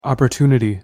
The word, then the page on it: opportunity: English